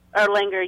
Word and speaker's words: Erlanger: Did I pronounce that correctly? Erlanger? – Erlanger, yes. – Erlanger. Is it Erlanger or Erlanger? – Erlanger. Any way you want to say it, everybody says it different.